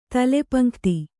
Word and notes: ತಲೆಪಂಕ್ತಿ: tale pan``kti; Kannada

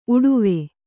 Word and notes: ಉಡುವೆ: uḍuve; kannada

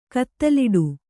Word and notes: ಕಿತ್ತಲಾಟ: Kannada

